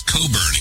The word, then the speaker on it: Cockburn: Bruce Cockburn. I keep pronouncing his name right. Somebody corrected me recently, and it's Coburn, excuse me, Coburn.